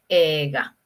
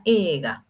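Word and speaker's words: ega (映画): Y recuerda que la palabra película se dice ega. ¿Sí? Ega. Se escribe eiga, pero se pronuncia ega, ¿ok?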